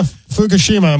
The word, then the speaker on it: Fukushima: Yeah, Fukushima. I'm glad you said it right.